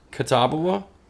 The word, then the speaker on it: Catawba: And this is from Catawba Brewing. Is that how you pronounce it? – I would guess Catawba. – Catawba? – Chumbawamba. – Chumbawamba Brewing. – Chumbawamba. No, I think it's Catawba. – Catawba. Okay.